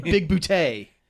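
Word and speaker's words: Bigbooté: like Smallberries and Big Bootay, and it's spelled Big Booty.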